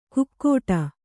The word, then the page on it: ಕುಕ್ಕೋಟ: kukkuōṭa